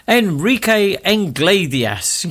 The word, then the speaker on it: Enrique Iglesias: Enrique Iglesias.